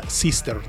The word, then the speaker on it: Systr: The Sister se escribe S-Y-S-T-R, pero se pronuncia Sister, de hermana en inglés.